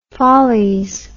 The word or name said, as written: folles